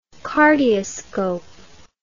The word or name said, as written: cardioscope